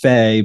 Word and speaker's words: Fahey: It's Faye, but it looks like Fahey.